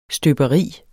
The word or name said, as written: støberi